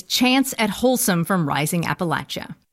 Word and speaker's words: Appalachia: I want to shout out Leah Song, who gave me the perfect mnemonic device for remembering how to pronounce Appalachia. Her grandmother would say, "It's like someone's throwing an apple at ya."